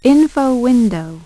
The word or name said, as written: Info Window